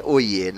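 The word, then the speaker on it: Høje: Hoje se escreve exatamente como se escreve hoje, mas a pronúncia pronuncia, a pronúncia é Oie, né? Porque o J deles lá é diferente.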